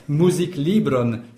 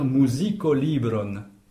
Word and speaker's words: musiklibron: Alors, musiklibron, c'est un peu difficile à prononcer, peut-être le cas suivi du L. On a tout à fait le droit de dire musikolibron. Si vous l'estimez plus facile à prononcer, vous pouvez.